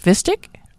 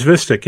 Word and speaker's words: Vistiq: The midsize Lyriq, the three-row Vistiq, correct? Vistiq? – I think it's Vistiq, yeah.